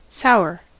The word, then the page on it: sour: /ˈsaʊ(ə)ɹ/; /ˈsaʊə/